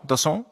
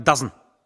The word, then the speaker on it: DAZN: Dazon. Oder wie Menschen über 50 sagen, Dazn.